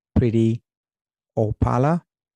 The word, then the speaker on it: Preity Upala: Again, that was Priti Opala.